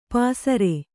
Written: ಪಸರೆ

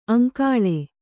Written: ಆಂಗುಲಿ

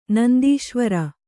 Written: ನಂದೀಶ್ವರ

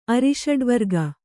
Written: ಅರಿಷಡ್ವರ್ಗ

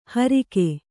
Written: ಹೋರ್ಕೆ

